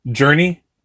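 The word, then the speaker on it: Jurnee: I think because I like Mary Elizabeth Winstead so much in this character, and I like Jermy Smollett-Bell, I'm probably mispronouncing that, aren't I? – Journey? – Okay, I was saying Jeremy for a second. Journey Smollett-Bell.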